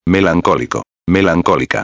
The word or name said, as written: melancolico-a